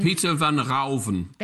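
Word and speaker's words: Pieter van Ruijven: And in your book, you write, a turning point in Vermeer's life was on 30 of November, 1657, when he owned, is it Pietra Ruven? Am I saying it right? Peter Ruven. – Peter van Rauven.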